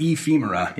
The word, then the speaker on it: ephemera: got your own type foundry, ephemera, if it pronounced right. I, I pronounce it ephemera.